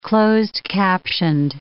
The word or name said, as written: closed-captioned